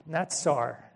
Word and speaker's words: natsar: It's natsar is how it's pronounced.